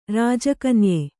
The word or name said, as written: ರಾಜಕನ್ಯೆ